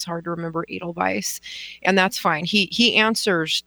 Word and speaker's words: Edelweiss: Elderweiss? – What? – Elderweiss? Your horse? – The dog? – No. – Edelweiss. – Edelweiss. Okay. I didn't know how you pronounced it. – Oh yeah, like the song, like the flower.